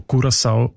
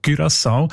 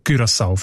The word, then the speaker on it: Curaçao: Das sind eben die Aussprachendatenbank, die haben nämlich gerade letzten Dezember die Aussprachempfehlung geändert von Curaçao auf Curaçao, weil sie beziehen sich dort auf Aussprachenduden und auf das digitale Wörterbuch von der deutschen Sprache, wo beide eben Curaçao vorschreiben.